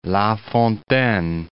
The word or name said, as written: La Fontaine